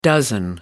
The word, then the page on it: dozen: American English